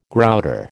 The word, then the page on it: grouter: American English